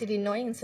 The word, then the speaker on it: Illinois: Se escribe Illinois, pero es Illinois, se silencio.